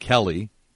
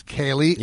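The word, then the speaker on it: Kaley: It's not Kelly. – It's Kaylee.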